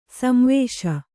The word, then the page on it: ಸಂವಾಸಿ: Kannada